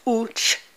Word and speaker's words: Uche: Uche Maduagwu is U-C-H-E. In Igbo pronunciation is Uche. So it's Uche.